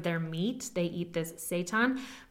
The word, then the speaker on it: seitan: I think it's seitan, S-E-I-T-A-N, seitan. All I'm thinking is Satan.